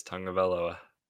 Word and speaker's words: Tagovailoa: I think it's a conspiracy, but uh, yes, the moment everyone's been waiting for since the draft, Tua Tagovailoa. – Tagovailoa. – There's no N. It's, there is no N. – I told you, it's Tagovailoa.